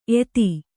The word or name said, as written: ಏದು